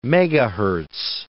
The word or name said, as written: megahertz